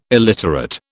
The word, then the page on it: illiterate: British English